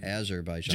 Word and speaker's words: Azerbaijan: Let's head right in to our Azerbaijan recap. Am I saying that right? – Azerbaijan.